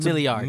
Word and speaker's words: milliards: We're all potential billiards in this room. – Mm-hmm. That's not. It's not. You didn't say that right. – Isn't it billiards? – Huh? It's milliards.